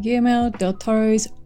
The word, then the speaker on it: Guillermo del Toro: And we're here to talk about Guillermo del Toro's Pinocchio. – Is that how you say it? – Well, you can say it like that.